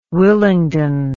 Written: Willingdon

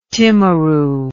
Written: timaru

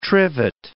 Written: trivet

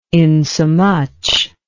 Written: insomuch

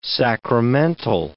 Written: sacramental

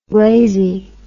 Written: glazy

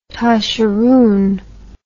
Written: tosheroon

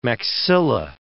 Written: maxilla

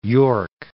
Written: Yorke Peninsula

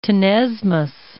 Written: tenesmus

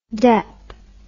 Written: dep

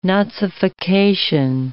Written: nazification

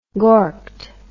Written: gorked

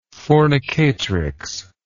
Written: fornicatrix

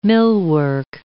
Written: millwork

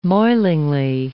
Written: moilingly

